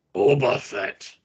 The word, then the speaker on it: Boba Fett: Boba Fett.